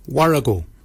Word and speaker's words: worragol: Barkin is spelled B-A-G-I-N-Y. Barkin. Barkin is your beard. The upper mouth, we say warrigal. Warrigal. Warrigal is spelled W-O-R-R-A-G-O-L, Warrigal.